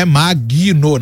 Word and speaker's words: Magno: Magno, M-A-G-N-O, não é Magno, não. Magno, né? O G não é mudo, mas ele é aquele G travadinho. Magno. Você fala o G, né?